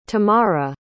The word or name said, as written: tamarah